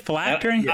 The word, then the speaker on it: phylactery: What would your philcantry be? – My phylactery. – Is that how you say it? Phylactery.